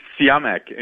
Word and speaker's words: Siyamak: Tell me, was I close? – It's Siyamak, and you got it right.